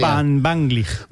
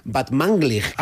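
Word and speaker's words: Batmanglij: Casi bien pronunciado, ¿eh? O sea, tercero de Radio 3 tienes casi. – Van Banglig. – Batmanglig.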